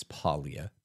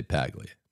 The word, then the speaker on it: Paglia: And yes, I know, you really say his name is Paglia. We're American, we say Paglia.